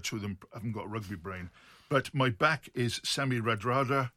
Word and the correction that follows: Radradra: Randrandra. – My back is Semi... Shut up. Can you speak Fijian or not? – Well, I can pronounce it, yeah. – Semi Randradra. – Randrandra. – Randrandra, right.